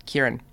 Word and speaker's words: Kerryn: Kerryn. Oh, Kerryn, how are you going? No, Kerryn. How you spell it? K-E-R-R-Y-N. No, mate, it's Kerryn.